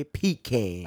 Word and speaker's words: pecan: Yes, it's pronounced pecan, not pecan.